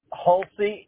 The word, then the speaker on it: Hulsey: Hulsey, H-U-L-S-E-Y.